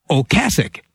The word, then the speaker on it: Ocasek: It's not Rick Ocasek or Ocasek, but Ocasek. Rick Ocasek.